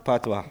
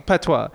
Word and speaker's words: patois: Patois. – Patois.